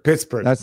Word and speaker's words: Pittsburgh: I think I'd probably put Pickard in against Schittsburg. – That's not how you say it. – Pittsburgh.